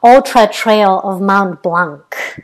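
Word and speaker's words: Ultra-Trail du Mont-Blanc: Oh, well, UTMB, it's really easy to pronounce, but it is the Ultra Trail of Mont Blanc. – Oh, well, that's exactly how I would have said it. – That is exactly how you would say it.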